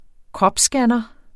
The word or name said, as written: kropsscanner